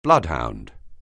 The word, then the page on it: bloodhound: anglais